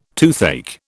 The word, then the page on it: toothache: British English